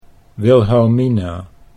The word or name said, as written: Wilhelmina